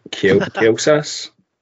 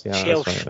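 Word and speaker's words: Kjelsås: I would I'd love to tell you who it is they're playing, but I don't think I can pronounce it. Kielsas? – Shells.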